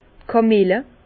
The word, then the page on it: d' Kamille: Bas Rhin; Herrlisheim